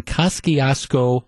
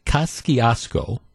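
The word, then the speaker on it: Kosciusko: Which brings us to what happened yesterday in Kosciuszko County, Indiana. And that's how you pronounce it. I checked that out. This was the research. Kosciuszko, Indiana, which is a rural county in kind of northwest to north central Indiana.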